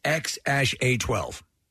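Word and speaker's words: X Æ A-12: I finally learned how to pronounce the kid's name. It's X-A-12. – X-A-12? – Yeah. – That's the way it's pronounced.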